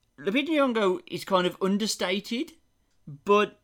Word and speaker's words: Lupita Nyong'o: Well, the other ones, you have Lupita Nyong'o.